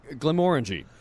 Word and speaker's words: Glenmorangie: Glenmorangie. – So the emphasis is on more. – The second syllable. Like Glenmorangie. You always want more of it.